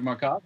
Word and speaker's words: Marcotta: Doug Marcotta, that's what I think it is. – Yeah. – Not Marcotta like ricotta cheese, but yeah.